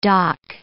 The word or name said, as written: DOC